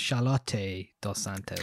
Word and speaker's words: Charlotte Dos Santos: According to her Instagram, it's pronounced Charlotte dos Santos.